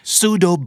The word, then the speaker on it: pseudo: pseudo bulber effect pseudo สะกดว่า p s e u d o ตัว p เป็น p ที่ไม่ออกเสียงนะครับเป็น silent p